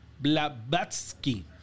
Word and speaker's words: Blavatsky: Elena Blablatsky, ¿sabe? Madame Blavatsky.